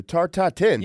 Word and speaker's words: Tarte Tatin: This might be a little bit out of my pay grade, but the Tarte Tatin, y'all ever heard of this shit? The Tarte Tatin? – The Tarte Tatin.